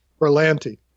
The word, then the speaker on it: Berlanti: I think that Bernance, how do you say his name? Bernance, the producer of these Berlanti. – Shows. Berlanti.